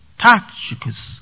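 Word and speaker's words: Tychicus: Now he sends Tashikas, if you want to put it on the first syllable pronunciation, Tashikas, to them, to convey what?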